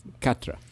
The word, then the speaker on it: quatre: Isn't it quatre? – Cat. – It's quatre. – No, that's not. It's pronounced cat. – No. – It's spelled. Are you sure? – I'm pretty sure. Un, deux, trois, quatre, cinq, six, sept, huit, neuf.